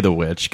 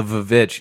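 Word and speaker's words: The VVitch: I feel the only, because you, I pronounce it the witch. I say the witch, guys. – Yeah, come but on. There are people out there who are like, oh, yeah, the Vavitch.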